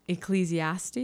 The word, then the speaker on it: Ecclesiastes: So I wanted to read the scripture from Ecclesiastes.